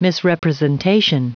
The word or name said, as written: misrepresentation